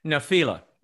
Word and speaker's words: Nephila: Nephila? – Nephila.